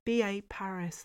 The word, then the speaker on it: B.A. Paris: I think if you've enjoyed other books by P.A. Barris, you will. Did I even say that right? Let's try again, Philippa. B.A. Paris. There you go.